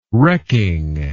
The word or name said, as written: wrecking